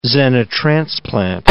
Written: xenotransplant

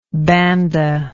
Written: banda

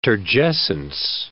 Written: turgescence

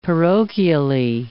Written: parochially

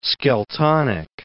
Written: Skeltonic

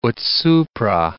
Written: ut supra

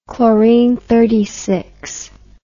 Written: chlorinous

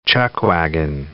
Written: chuckwagon

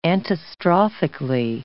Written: antistrophically

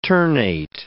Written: ternate

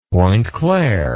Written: pointe-claire

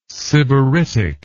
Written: sybaritic

